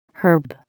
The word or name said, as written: herb